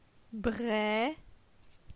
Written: Bre

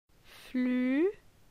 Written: Flu

